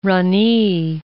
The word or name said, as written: ranee